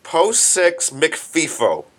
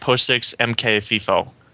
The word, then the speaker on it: mkfifo: Fix an open baster bypass in POSIX McFIFO. There's a function in PHP called POSIX McFIFO. POSIX MKFIFO. – I think it's pronounced POSIX MKFIFO.